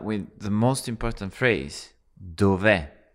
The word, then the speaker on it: dov'è: So remember to put the stress on the last part of the word. So it's dove.